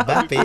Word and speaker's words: Mbappé: C'est pour ça que normalement, les journalistes français, quand ils appellent Mbappé, nous, en tant que Camerounais, on dit non, le M ne se prononce pas. Parce que, ça vous fait Mbappé. Rigoler, quoi. – Donc, Mbappé.